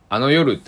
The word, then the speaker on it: あの夜: あの夜って書いて、あの夜って読みます。